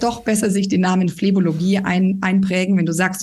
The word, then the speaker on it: Phlebologie: Und habe ich Phlebologie richtig ausgesprochen? – Plebologie klingt genau wie es sein sollte.